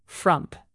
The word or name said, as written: frump